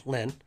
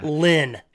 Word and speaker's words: Lyn: The killer, her name is spelled L-Y-N, Lynn. – Right. – He spells her name on her forehead with nails. Nails embedded in her forehead. – Yeah. – Lynn. – L-Y-N.